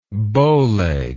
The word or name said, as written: bowleggedness